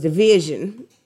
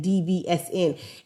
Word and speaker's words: DVSN: And honestly, I didn't even know that their name was Division at first, because it's spelt DVSN. So that's what I was saying. I was saying DVSN.